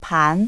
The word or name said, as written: pan